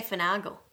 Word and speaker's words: finagle: How do you say finagle? – Finagle.